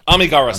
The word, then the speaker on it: Amigara: The actual pronunciation of that, Kamigawa Fault. – Is it Amigara? – Amigara. Sorry, thank you.